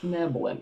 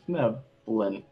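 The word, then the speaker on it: Svirfneblin: So this is for Fneblin. – It's Fneblin. – Yep, that's right. – I'm going to have a heck of a time saying that. Thank you. – Yeah, three syllables. It's for Fneblin.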